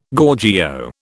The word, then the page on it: Gorgio: British English